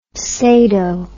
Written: sado

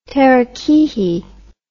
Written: terakihi

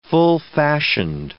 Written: full-fashioned

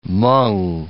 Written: Hmong

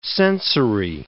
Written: sensory

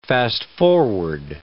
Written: fast-forward

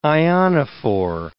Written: ionophore

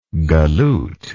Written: galloot

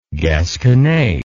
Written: gasconadeer

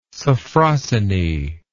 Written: sophrosyne

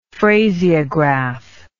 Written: phraseograph

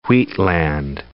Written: wheatland

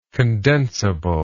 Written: condensibility

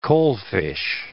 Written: coalfish

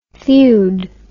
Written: thewed

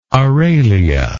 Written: aralia

